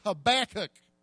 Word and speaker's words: Habakkuk: his name is Habakkuk.